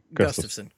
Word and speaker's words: Gustafsson: I think Gustafsson. I always forget how to say his name. – Gustafsson. – Yeah.